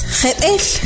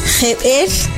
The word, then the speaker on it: jebel: Shot se escribe X-O-T. Y al referirnos a la palabra mágica, como ya les expliqué, para nosotros es algo maravilloso, es Jebel. Jebel se escribe J E, B, glotal, E y L.